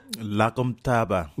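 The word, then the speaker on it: Lagem Taaba: L'Agomtaba. – Voilà, ça s'écrit L-A-G-E-M, trait d'union, T-A-A-B-A.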